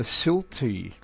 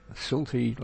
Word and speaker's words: Silte: Program in the Silti language, that's spelled S-I-L-T-E, Silti language, signing on at 1730.